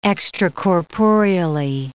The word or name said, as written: extracorporeally